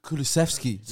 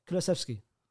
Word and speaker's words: Kulusevski: Kulusevski. There's no CH.